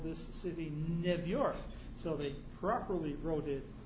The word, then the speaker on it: New York: Anyone who speaks English properly knows that the EU sound in the English language is phonetically U. It's pronounced New York. Only Jews didn't pronounce it New York, they pronounced it New York.